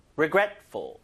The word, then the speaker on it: regretful: I feel regretful. 아, 좀 후회스러워. 그죠? 후회스러워. I feel regretful. regretful. 강세 잘 보세요. regretful.